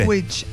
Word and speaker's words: Ipswich: ¿Cómo se pronuncia? Porque yo le digo Ipswich. El IPS. ¿Viste que es IPS Witch? Ipswich. El Ipswich Town. No, es tan fácil. Por eso los muchachos le solucionan con IPS nomás ya. Ipswich. – Sí, así mismo es. Ah, no, yo pensé que tenía una pronunciación diferente a cómo se escribe. – Ipswich, así mismo es. – Así como se escribe, se lee.